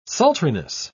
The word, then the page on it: sultriness: British English